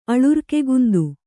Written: ಅಲರ್ಕೆಗುಂದು

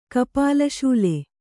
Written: ಕಪಾಲಶೂಲೆ

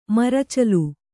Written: ಮೋರ್ಚಾಲ್

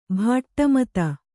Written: ಭಾಟ್ಟಮತ

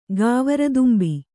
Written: ಗಾವರದುಂಬಿ

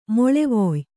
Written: ಮೊಳೆವೊಯ್

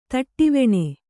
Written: ತಟ್ಟಿವೆಣೆ

